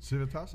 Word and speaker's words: Civitas: Representative Anchilla is a co-founder and managing director in the global investment firm Civitas. Is that how you say it? – Civitas. – Uh-huh. Civitas, yeah. Capital Group.